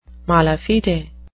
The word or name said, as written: mala fide